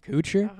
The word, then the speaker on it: Kutcher: Kutcher.